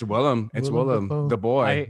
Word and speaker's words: Willem: I used to say William like Goblin. So much, like, and then somebody corrected me. I was like, whoops, I guess I've been saying his name wrong for like years. – Yeah, and it's Willem as in Will E-M.